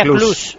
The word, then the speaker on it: Cluj: Aeropuerto Club Napoca. – No, se pronuncia Cluj. Eso es.